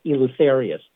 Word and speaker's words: Eleutherius: It's like Luther with an E in front of it to pronounce it. So Eleutherius.